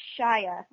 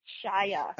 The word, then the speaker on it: Shia: Oh, okay, so it's Shia. Okay, sorry. I keep mispronouncing his name. So, Shia.